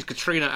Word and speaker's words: Catriona: She's actually, you did what a lot of Americans do and go for pronouncing every vowel. She's actually got lots of silent vowels. She's Katrina as in the hurricane. – It's Katrina. – The Scottish, it's the Scottish spelling of Katrina.